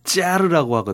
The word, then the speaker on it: 짜르: 사실은 차리즘이 더 그 본래 발음에 맞 다고 봅니다 저는. 왜냐하면 러시아의 황제를 짜르라고 하거든요. – 네. – 짜르. – 아 짜르. 네. – 뭐 차르라고도 씁니다만 어쨌든 이 짜르를 중심으로 한 제정 러시아의 전제적인 정치 체제 자체를 차리즘이라고 해요.